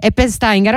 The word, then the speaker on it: Eppensteiger: Quindi siamo in diretta con Federico Bacci e Francesco Eppensteiger Eppensteiger, allora avevo detto bene prima, registi di Radio Soler, radiodiffusione rurale, che appunto, raccontami tu dove vi trovate e come sta andando.